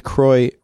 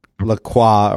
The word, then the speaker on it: Croix: And it is not pronounced. It is pronounced Croix, not La Croix, or however people like to get fancy and try and say it.